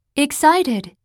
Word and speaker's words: excited: Excited Excited CI の部分を一番強く SI と発音してね。